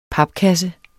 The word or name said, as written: papkasse